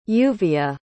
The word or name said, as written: lluvia